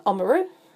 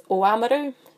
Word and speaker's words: Oamaru: As is growing up, it was Omaru. It's, if I'm being correct, it's Oamaru, Timaru, Timaru, and yeah, just lots of examples around that.